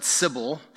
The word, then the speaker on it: Cybele: And at that point, it was dedicated to another local goddess, usually referred to as, I'm going to pronounce it Cybel, C-Y-B-E-L-E, who was identified with Artemis.